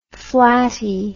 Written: flattie

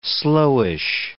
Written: slowish

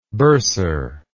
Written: bursarship

